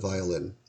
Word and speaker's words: viol: The musical instrument of which we speak is, in English, the viol, spelled V-I-O-L. It is pronounced exactly the same as V-I-A-L, a small glass container for holding liquids, and V-I-L-E, an adjective meaning disgusting or loathsome.